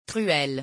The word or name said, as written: truelle